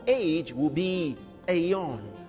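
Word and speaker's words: aion: You see, aeon, this age.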